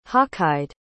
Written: hawkeyed

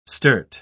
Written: sturt

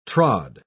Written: trod

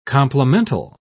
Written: complemental

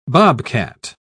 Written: bobcat

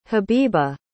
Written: habibah

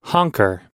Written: honker

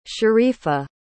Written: sharifa

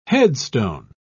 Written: headstone

